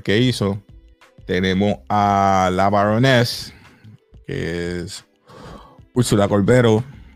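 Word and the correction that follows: Corberó: Corbero, perdón, con acento en la O.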